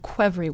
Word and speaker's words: Qvevri: It's called Quevri wine, and it's spelled Q-V-E-V-R-I.